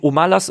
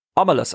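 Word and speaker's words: Omelas: Ormelas. Woher kommt der Name? – Ja. Es wird nicht Ormelas, sondern es wird Ormelas ausgesprochen. So ähnlich wie Oregon.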